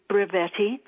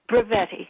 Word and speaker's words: Brevetti: I like to say it rhymes with spaghetti. My last name is Brevetti, and it's spelled B-R-E-V as in Victor, E-T-T-I.